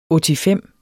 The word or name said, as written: ottifem